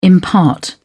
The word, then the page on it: impart: British English